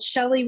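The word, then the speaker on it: Chelli: And I'm assuming you might have that written down, because my name is spelled Shelly with a C-H-E-L-L-I, Pumphrey, so it's not easy to think of.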